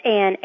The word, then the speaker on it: Anne: Whenever anyone asks me my name, I always need to say Anne Berg, B-U-R-G. Oh, and that's Anne, A-N-N, with no E at the end, unless you want to include my middle initial, which is E, for Elizabeth.